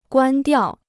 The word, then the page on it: 关掉: guān diào